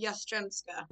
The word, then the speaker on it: Jastrzembska: Very nearly, Ali. Jastrzembska.